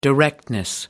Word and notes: directness: British English

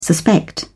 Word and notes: suspect: British English

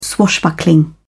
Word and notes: swashbuckling: British English

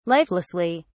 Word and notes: lifelessly: British English

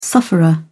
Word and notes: sufferer: British English

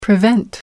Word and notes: prevent: American English

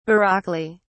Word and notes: baroquely: British English

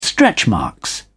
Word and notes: stretchmarks: British English

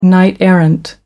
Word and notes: knight-errant: American English

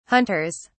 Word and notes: hunters: British English

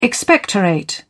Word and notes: expectorate: British English

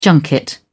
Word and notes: junket: British English

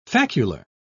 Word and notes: facular: British English

